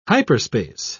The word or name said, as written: hyperspace